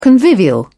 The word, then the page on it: convivial: British English